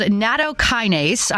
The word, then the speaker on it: Nattokinase: It's called Nattokinase.